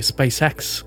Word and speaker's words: SpaceX: Сюда могут допрыгнуть игроки, например, та же SpaceX, или SpaceX, как меня иногда исправляют, но мне все же кажется, когда я говорю по-русски, говорить SpaceX абсолютно нормально.